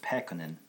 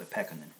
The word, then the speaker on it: Pekkanen: It's a Finnish name, and in general, when you pronounce Finnish words, the first syllable gets a little accent on it, Pekkanen. A little stress. So Pekkanen is how you say it, yeah.